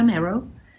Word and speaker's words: Marro: My name is Margareta Morrow. That is spelled M-A-R-R-O.